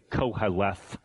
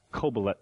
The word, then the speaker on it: Qoheleth: It looks like, you'll see it sometimes if it's transliterated, it's kind of a funny spelling, Q-O-H-E-L-E-T-H. We would sort of say koheleth, but it's pronounced kobelet in the Hebrew.